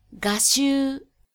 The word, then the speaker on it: 我執: 所谓我执，即执着于作为个人主体的自我的存在，日语念作 ga shu。